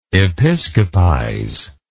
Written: episcopize

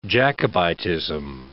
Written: Jacobitism

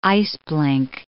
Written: iceblink

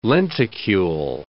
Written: lenticule

